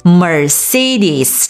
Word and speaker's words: Mercedes: Mercedes, 这是一个 famous German car brand, Mercedes。